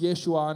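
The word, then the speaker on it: Yeshua (ישוע): So in the same way that the person that may never hear the actual word of Jesus, which is our English interpretation of a word that's pronounced Yeshua,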